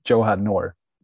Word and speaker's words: Johan Noor: Honestly, I think it's a bit difficult for me as a Swede to try kind of say my name in English because I'm not used to it saying it in English. I think it's Johan Noor.